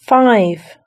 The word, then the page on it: five: British English